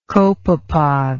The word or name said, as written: copepod